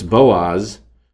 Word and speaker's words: Boaz: Where you get the idea that in him is strength, Bo is in him, and Ots or Ats, O-T-S is actually how it would be spelled, but you could pronounce it almost like the word Oz, O-Z. So it's Boaz.